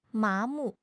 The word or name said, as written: 麻木